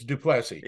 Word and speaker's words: Duplessis: The S on the end is silent because of where he comes from. It's Duplessis.